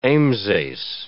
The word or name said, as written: ambsace